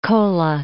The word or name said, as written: Kola Peninsula